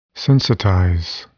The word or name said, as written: sensitize